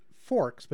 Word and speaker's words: FRKSE: Before that was the track Conceits by an outfit that I believe is pronounced Forks, but is styled in all majuscule letters as F-R-K-S-E.